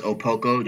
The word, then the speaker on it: Okpoko: So my Instagram handle is Charles Opoko, just my first and last name.